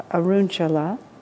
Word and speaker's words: Arunachala: I mean, I used to have more fixed opinions about these things, but I'm in India, the Arunachala, that's how it's pronounced, the place where Ramana Maharshi has his Arunachala.